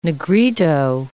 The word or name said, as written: Negrito